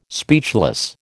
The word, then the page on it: speechless: American English